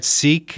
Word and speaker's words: Sikh: Am I saying that right? Sikh.